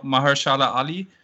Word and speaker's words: Mahershala Ali: Also Mahershala Ali.